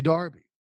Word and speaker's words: Derby: So, again, you say Darby, I say Derby, but because it matters how you say it, because it's yours, I say Darby.